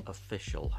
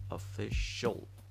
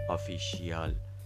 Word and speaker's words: official: official هست اگه دقت کرده باشین من میگم official شل پس شیال تلفظ من نمی کنم